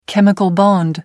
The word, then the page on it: chemical bond: British English